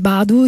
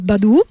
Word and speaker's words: Badu: Faccio una premessa: ho comprato il primo album di Erika nel 99, io l'ho sempre adorata, Erika Badu, tra l'altro, che ultimamente sento chiamare Badu in continuazione da tutti, in realtà è Badu, come lei stessa ci dice più di una volta anche nei suoi live, in cui le sue coriste pronunciano il nome infinite volte.